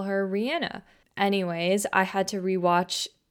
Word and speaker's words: Rihanna: But I started to think like, wait, didn't she say that her name is actually pronounced Rihanna?